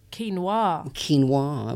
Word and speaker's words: quinoa: Quinoa. – Am I saying it right? – Quinoa, yeah. – I say quinoa. But quinoa. – You stress the A.